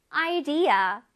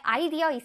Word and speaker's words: idea: Idea. 생각, 의견이란 뜻입니다. 우리 친구들이 아는 단어야. 우리 왜 아이디어 있어? 아이디어 이러잖아요. 우리 이제 아이디어라고 안 하고 idea라고 멋있게 발음해 주세요.